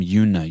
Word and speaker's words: Joona: I like to call him Yuna. You're talking about J-O-O-N-A? – It might be Yuna. I'm not no, sure. No, they pronounce it like you do.